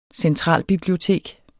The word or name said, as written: centralbibliotek